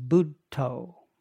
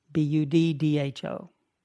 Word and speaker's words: Buddho: A mantra, it's two syllables, Buddho, Buddho.